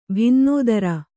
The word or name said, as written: ಭಿನ್ನೋದರ